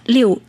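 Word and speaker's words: liều (六): Lục lục đại thuận thì có nguồn gốc từ ờ, kinh dịch trong ờ, kinh dịch có nói đến 6, ờ, trong tiếng Trung đọc là liều, âm Hán Việt là lục,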